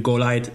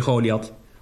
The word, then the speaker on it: Goliath: Oftewel de Goliath, de Goliath. Dit is maar net waar de klemtoon legt.